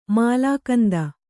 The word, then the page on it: ಮೂಲಕಂದ: mūlakanda